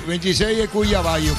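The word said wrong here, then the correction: Cuiabano: ¿Cómo se pronuncia? – Cuyabano. – Cuyabano, sí.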